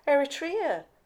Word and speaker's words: Eritrea: Eritrea? – Yeah.